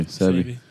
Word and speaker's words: Sebi: Sebi? – Yeah, Sebi.